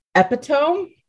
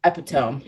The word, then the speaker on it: epitome: I don't know if this is considered easy, but it's epitome. Like epitome. – I don't even know what is that. – Epitome. Here. Epitome. Spell it. E-P-I-T-O-M-E. – Now, if I saw that without you telling me, I probably would have said epitome. – It's pronounced epitome. – Oh. Okay, epitome.